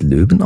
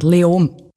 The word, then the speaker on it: Leoben: Spricht man es Löwen aus? Wie spricht man das überhaupt aus? – Eigentlich fast kein Konsonant. Leom.